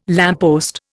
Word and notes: lamppost: Indian

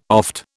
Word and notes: oft: British English

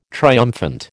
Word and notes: triumphant: American English

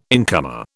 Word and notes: incomer: British English

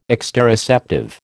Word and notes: exteroceptive: American English

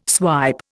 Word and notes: swipe: Indian

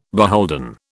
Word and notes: beholden: British English

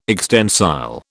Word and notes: extensile: British English

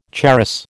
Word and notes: charas: American English

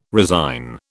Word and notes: resign: British English

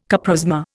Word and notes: coprosma: Indian

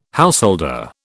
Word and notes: householder: British English

